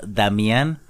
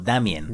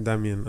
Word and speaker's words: Damien: Dato curioso, eh, pues bueno, eh, lo conocen ustedes como Damián, pero pues en el episodio supongo que se dieron cuenta que lo pronuncian como Damien. Damien, así es.